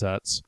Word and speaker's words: Utz: But it rhymes with butts. It's Utz.